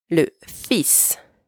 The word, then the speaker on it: fils: And careful here, there's an L that is silent. You do not pronounce it. Le fils.